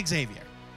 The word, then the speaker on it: Xavier: It's Xavier. You pronounce the X. – I'm not doing it. I know the school is pronounced with a Z sound.